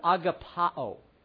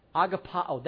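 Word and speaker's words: agapao (ἀγαπάω): is the word agapao, or you may have heard it pronounced agape. Agapao.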